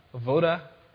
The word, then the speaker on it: avodah: It's avoda, A-V-O-D-A-H.